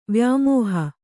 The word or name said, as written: ವ್ಯಾಮೋಹ